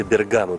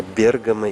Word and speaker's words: Бергамо: У нас наша традиция Бергамо, потому что был фильм Труффальдино из Бергамо. Но настоящее ударение правильное – это Бергамо.